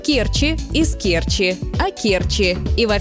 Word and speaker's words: Керчи: Нередко можно услышать вариант «в Керчи», но это ошибка. Правильно – в Керчи, с ударением на первый слог.